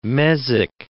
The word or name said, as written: mesic